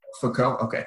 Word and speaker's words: Foucault: Foucault.